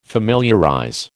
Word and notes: familiarize: American English

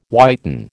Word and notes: whiten: American English

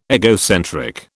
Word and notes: egocentric: British English

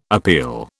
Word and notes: appeal: British English

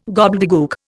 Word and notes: gobbledegook: Indian

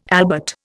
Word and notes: albert: Indian

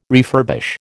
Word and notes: refurbish: American English

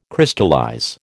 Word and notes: Crystallize: American English

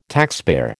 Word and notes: taxpayer: American English

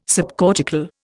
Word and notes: subcortical: Indian

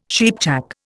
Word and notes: cheapjack: Indian

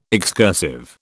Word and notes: excursive: British English

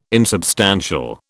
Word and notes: insubstantial: British English

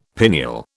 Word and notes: pineal: British English

